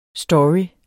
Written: story

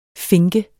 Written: finke

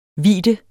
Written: vidde

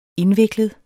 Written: indviklet

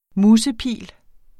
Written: musepil

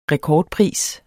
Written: rekordpris

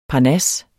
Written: parnas

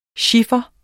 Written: chiffer

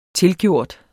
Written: tilgjort

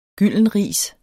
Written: gyldenris

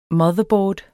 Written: motherboard

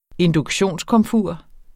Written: induktionskomfur

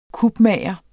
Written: kupmager

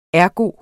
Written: ergo

